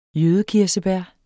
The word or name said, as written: jødekirsebær